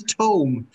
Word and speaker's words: tome: Bill Murray pulls out the old tomb of animals, strengths and weaknesses. – That was Tome. – I'm sorry, Tome. I pronounced it wrong.